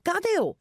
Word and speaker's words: Cadejo: Gadeo is how you pronounce their name, but for those of you who are reading this in English, we are going to butcher this if we just saw the English spelling of the way that they write out their team name. So C-A-D-E-J-O.